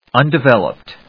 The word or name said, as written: undeveloped